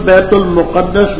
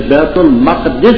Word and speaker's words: بیت المقدس: بعضے کہتے ہیں بستی سے بیت المقدس مراد, اس کا تلفظ بیت المقدس بصیرہ اس میں مقبول بھی ہے, اور نووی رحم اللہ تعالی وغیرہ نے تصریح کی ہے کہ بیت المقدس بھی پڑھ سکتے ہیں, تہذیب الاسماء واللغات وغیرہ میں تصریح ہے.